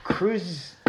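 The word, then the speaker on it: Kruszyniany: And in the, I apologize for the butchering, Tartar village of Cruzignani. – Yes, that is how it's perfect. – Pronounced.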